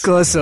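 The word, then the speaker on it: CURS_R: Ridley Scott is making a show called Curse, well, how do you say this? Capital C, capital U, capital R, capital S, underscore R. – Jesus. – Cursor. Man. But with the O missing.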